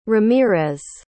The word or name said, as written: ramirez